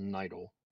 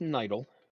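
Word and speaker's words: Nidle: My last name is Nidel. It's the word idle with an N in front of it. So it's N-I-D-L-E. And it's pronounced Nidel.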